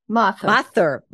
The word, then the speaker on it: Mathur: Avantika Mathur. Did I do that okay? – That's very good. – Ooh. – Mathur. – Mother. – Mother. Okay.